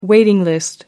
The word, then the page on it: waiting list: American English